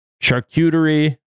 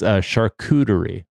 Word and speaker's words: charcuterie: Charcuterie is not the way to pronounce that, and is definitely how I've been pronouncing it. It's charcuterie. It's the thing to remember. Charcuterie isn't cute. So mind that.